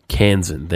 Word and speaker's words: Kansan: Kansan, thank you.